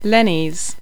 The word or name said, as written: Lennies